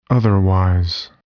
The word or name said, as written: otherwise